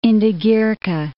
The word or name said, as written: Indigirka